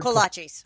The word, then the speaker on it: kolaches: Kolaches.